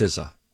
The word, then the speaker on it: SZA: According to Elite Daily, who wrote an entire article about how to pronounce the artist's name spelled S-Z-A. – Oh, great. – It is, you were right originally, Donna. It's SZA, pronounced S-I-Z-Z-A.